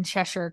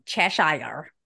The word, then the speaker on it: Cheshire: Halers would say that Harry was born in Cheshire County. Are you proud of me for saying that right? – Yeah, I actually am. Cheshire. – Cheshire is how I used to would have said that.